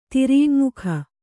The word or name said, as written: ತಿರಿಯಙ್ಮುಖ